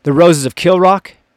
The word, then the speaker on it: Kilravock: But the 16th Laird, who is now in the chief during the Roses of Kilrock, it's spelled Kilravock, but it's pronounced Kilrock.